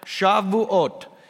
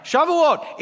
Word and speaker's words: Shavuot: Frank, did I pronounce that right? Shavuot. He's my Hebrew checker. All right, Shavuot, it is a feast in which all of these Jews from all these different countries come.